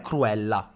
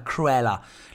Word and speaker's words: Cruella: È per questo che eh, sto caricando la pronuncia, perché non è Cruella, come lo diremmo all'italiano, è Cruella.